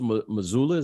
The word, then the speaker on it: Mazzulla: And I think it's going to be a tough season because now, you know, and Joe, you know, Coach Mazzulla, is that how you pronounce his name? – Yep.